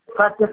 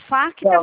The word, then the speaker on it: Facta: Chama Facta Financeira. – Tá, tá. Tá. – É F-A-C-T-A, tá? Ele é mudo, o T. O C-T.